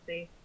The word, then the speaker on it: Genasi: Is that pronounced Genasi or how is that pronounced? – I say Genasi.